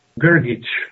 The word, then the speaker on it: Grgic: Grgic.